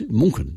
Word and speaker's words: München: When they say, oh, I want to pronounce it like the locals, and I say, well, how are you going to pronounce Munich in future? – München, isn't it? – It's München. Yes, it's München in Germany.